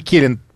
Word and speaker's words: Келин: России при ОБСЕ Килин говорит... Так, подождите, нет, подождите, какой России при ОБСЕ? Нет, все правильно. А постпред России при ОБСЕ Андрей Келин.